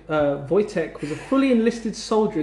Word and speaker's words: Wojtek: It's spelt W-O-J-T-E-K. – Sorry, W-O-J. – Can I read the word? – T-E-K, and it's spelt, and it's pronounced Wojtek.